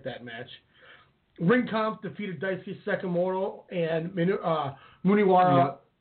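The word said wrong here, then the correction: Munenori Sawa: Sawa. – Muninori Sawa.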